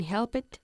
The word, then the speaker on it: helped: El verbo help no acaba ni en T ni en D, ni tampoco en un sonido de T o D, ¿ok? – Ok. – Por lo que hay que decir helped. – Helped.